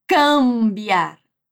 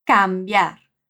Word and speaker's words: cambiar: Isso também acontece com o verbo mudar, trocar, que é cambiar. O M depois da A é nasal, mas a A não é tão puxada por ele como em português. Então a gente não diz cambiar, mas sim cambiar.